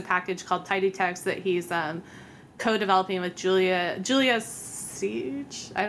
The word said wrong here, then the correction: Silge: don't know how to say her last name, actually, I'm realizing, but Silg, well, it's like Silg.